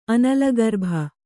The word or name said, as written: ಅನಲಗರ್ಭ